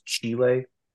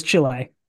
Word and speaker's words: Chile: I don't want to correct you, but I think it's pronounced Chile. – Sorry. I'm white, if you can't tell. So He pronounced it in white. – Yeah. Yes, you're right. It is Chile.